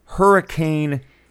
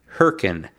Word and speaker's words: Hurricane: Tell you what, we'll let talk first because he spent a weekend in the wind and the dirt and in a town that looks like it should be pronounced Hurricane Utah, but that would be a mistake, wouldn't it? – Oh, yeah. The locals would correct you and say it's pronounced Hurricane, which adjoins Laverkan, Utah, and is very close.